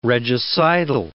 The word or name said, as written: regicidal